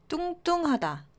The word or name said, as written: 뚱뚱하다